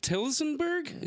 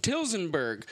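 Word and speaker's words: Tillsonburg: Two, Deborah is from Tilsonburg. Is that how you say it? – Yep. – Tilsonburg, which is a town in Oxford County, Ontario, Canada.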